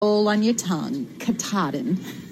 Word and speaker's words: Katahdin: Yeah, Katahdin. – Katahdin, yeah.